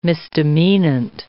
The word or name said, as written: misdemeanant